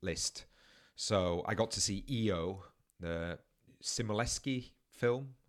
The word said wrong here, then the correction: Skolimowski: Is that his surname? Am I pronouncing that correctly? – Skolimowski. – Apologies, I didn't have it in front of me.